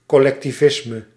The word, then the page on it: collectivisme: IPA: /kɔlɛktivˈɪzmə/